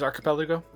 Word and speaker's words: archipelago: I think it's a K sound. – It is Archipelago? – Right.